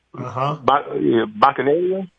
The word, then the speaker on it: Bacchanalia: Bacchanalia. Am I saying it correctly? – Yeah, you are. It can go either way.